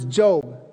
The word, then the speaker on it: Job: It's a guy's name spelled Job, okay? But his name is actually how you pronounce it is Job.